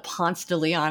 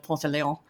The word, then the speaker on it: Ponce de Leon: Also, for those of you who don't live in Atlanta, we do call it Ponce de Leon Avenue instead of Ponce de Leon, as it should be pronounced.